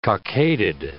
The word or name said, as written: cockaded